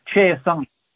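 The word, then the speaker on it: Chiasson: I love that you are giving the proper French pronunciation to Macy Chason.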